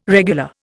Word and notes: regular: Indian

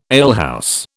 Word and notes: alehouse: British English

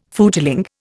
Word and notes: footling: Indian

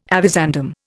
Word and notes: avizandum: Indian

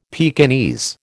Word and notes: Pekingese: American English

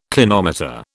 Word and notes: clinometer: British English

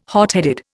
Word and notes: hotheaded: Indian

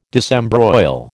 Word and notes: disembroil: American English

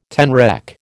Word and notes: tenrec: American English

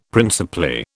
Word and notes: principally: British English

